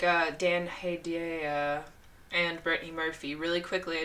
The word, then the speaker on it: Hedaya: I'm sorry, I don't know how to pronounce his last name. It's Hedaya.